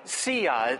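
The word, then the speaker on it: Tziyah: Tzia.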